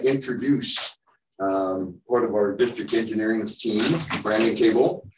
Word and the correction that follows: Cobble: It's Cobble, Cobble. – Cobble. Okay, I've always just said Brandon.